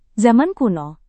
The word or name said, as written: zaman kuno